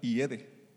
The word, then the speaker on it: hiede: Decimos, ya hiede. No es una H muda, hiede.